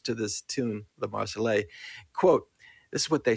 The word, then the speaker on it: Marseillaise: How do you say it? Marseillaise. – La Marseillaise. All right.